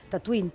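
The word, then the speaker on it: Tatooine: I de fet, George Lucas i els productors van viatjant per Tunísia, van descobrir un poblet que es deia semblant, pronunciat es deia igual, Tatuín, però s'escriu amb una lletra diferent.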